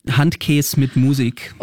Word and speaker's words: Handkäse mit Musik: Handkäse mit Musik.